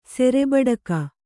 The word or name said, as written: ಸೆರೆಬಡಕ